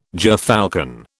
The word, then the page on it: gerfalcon: British English